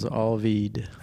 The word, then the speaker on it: Alveed: My cosmic name was Alveed. – How do you spell that? – A-L-V-E-E-D.